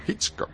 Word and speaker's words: Hitchcock: No, Hitchcock.